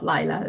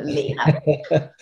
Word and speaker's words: Leila: Great, but pronounced Leela, not Leila, Leela. Not Leila, not Lila, Leela.